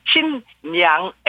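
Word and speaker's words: 신냥 (新娘): 하지만 신부는 신냥, N 발음입니다. 신냥 해가지고 신랑 신부하면은 신랑 신냥입니다.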